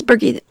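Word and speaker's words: Birgitte: We see Birgitta / Bridget. I can never remember how to say her name. – It's Birgitta.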